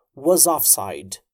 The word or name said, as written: was offside